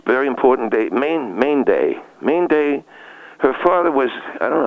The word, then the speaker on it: Mainday: M-A-I-N-D-A-Y. – M-A-I-N? – No, M-A-I-N-D-A-Y. – M-A-I-N-D-A-Y. – Yes. – That's M-A-I, Main Day. – Yes, that's, her name is, yes, it's pronounced Main Day.